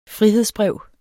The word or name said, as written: frihedsbrev